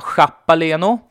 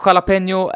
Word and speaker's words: jalapeño: Ja, jalapeño är ju ett galet svårt ord att uttala om man inte har koll på hur J uttalas i spanskan. Och dessutom så har du ju sånt där N med en liten krumelur ovanför sig också.